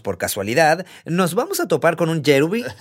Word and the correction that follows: kerubim: ¿Cómo se pronuncia? Eh, Kerubim. Kerubim.